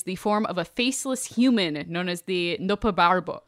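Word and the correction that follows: Nopperabō: I mean, also, it's nope how you pronounce it, but it's Noparabo.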